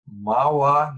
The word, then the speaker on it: Mahwah: Today, I have the privilege of interviewing Rob Parker, who is the leader of something called the Plant Church in Mahwah, New Jersey. Did I say that right? – Yes, you did.